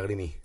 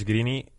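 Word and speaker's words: Greene: Eh, era muy raro que Maurice Grini eh, hubiese llegado. Yo, digo Green, pero es verdad que se escribe Greeny.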